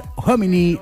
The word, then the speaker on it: Homini: Todo aquello que buscas lo encontrás a pasitos del municipio, en Artigas entre Varela y Puig, la línea de joyas y alhajas Homini, o Homini, como quieras llamarlo, porque la H no se pronuncia, pero se escribe Homini.